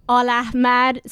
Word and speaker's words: Aleahmad: Aleahmad.